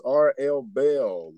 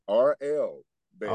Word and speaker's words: R.L. Bell: So my name is R.L. Bell. That's B-E-L-L. – Okay. R. Bell, right? – R.L. Bell.